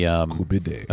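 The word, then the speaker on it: koobideh: I just get the Kubita kebab, which is it's actually, I think they pronounce it Kubita. But that's the, Kubide.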